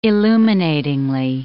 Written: illuminating

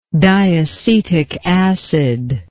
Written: diacetic acid

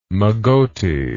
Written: mogote

